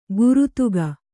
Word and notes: ಗುರುತುಗ: kannada

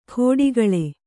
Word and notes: ಖೋಡಿಗಳೆ: khōḍigaḷe

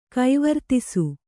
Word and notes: ಕೈವರ್ತಿಸು: kaivartisu

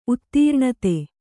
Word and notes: ಉದೀರ್ಣತೆ: udīrṇate

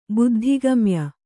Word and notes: ಬುದ್ಧಿಗಮ್ಯ: buddhi gamya

